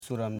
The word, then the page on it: suram: su.ram